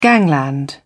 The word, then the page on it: gangland: British English